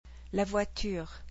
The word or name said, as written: La voiture